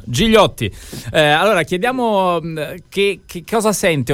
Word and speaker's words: Gigliotti: E noi lo chiediamo allora a Guilherme Gigliotti, o Gigliotti, adesso ci dirà lui se è meglio la pronuncia in francese o in italiano.